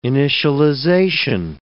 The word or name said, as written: initialization